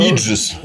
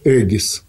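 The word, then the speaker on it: Aegis: Да, Иджис. – Да. – Все время называют Иджис, а оно ну, же эгида. – Иджис – это английское произношение. Да, да. Греческое – эгис.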